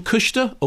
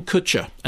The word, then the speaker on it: Kutcher: but on the question of how do you pronounce his name, Kushta or Kutcher, he's Ashton Kutcher, and the film is complete chit.